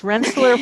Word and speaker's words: Rensselaer: Going back to her education, she graduated from Rensselaer. Did I say that right? – Yes, Rensselaer Polytechnic Institute.